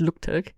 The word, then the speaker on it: Luktelk: Ich frage mich gerade, warum ich es Englisch ausgesprochen habe, weil es ist ja tatsächlich kein englischer Begriff, das heißt wirklich Luktelk.